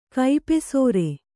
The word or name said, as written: ಕೈಪೆಸರ